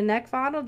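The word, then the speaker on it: Neckvatal: Over on YouTube and LinkedIn, we've got Brenda Neckfaddle, just using my name, and that's spelled N-E-C-K, like the thing you want to choke, V as in Victor, A-T-A-L.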